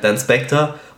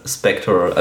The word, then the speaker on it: Spectre: Also, wenn du ganz deutsch aussprechen willst, dann Spectre und sonst heißt Spectre.